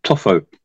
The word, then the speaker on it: Tofo: Then if we move further up the coastline in Mozambique, in sort of central Mozambique, there's a place quite well known called Tofu. That's how you want to say it. It's spelled T-O-F-O.